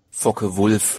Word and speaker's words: Focke-Wulf: Focke-Wulf.